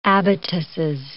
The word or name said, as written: abatises